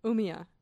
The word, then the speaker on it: Umeå: If you were to try and pronounce Umeå. – It. Umeå.